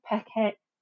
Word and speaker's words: Peckham: Yeah, Peckman and Brixton. I think that's Peckham.